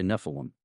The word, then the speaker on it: Nephilim: No, but what, am I incorrect, though, to say Nephilim? – No, it's totally fine. So the anglicized pronunciation would be Nephilim.